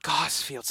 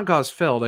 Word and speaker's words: Gosfield: Gosfield, so that's how it's pronounced. – Well, because it's not Gosfeld. I think there's an I in there.